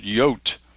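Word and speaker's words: Jot: Their letter is called Yot, because it's pronounced like a Y, just like the Greek, Yota.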